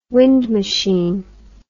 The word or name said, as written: wind machine